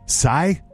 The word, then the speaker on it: scythe: How do you say that? S-C-Y-T-H-E. Scythe? Sigh? Sigh. It's a sigh. I believe sigh. That's how you say yeah.